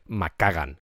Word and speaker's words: McKagan: pero Doug McCagan y Steven Adler hacían una pareja tremenda. Que por cierto se me hace muy raro decir McKagan, pero es la forma correcta de pronunciarlo.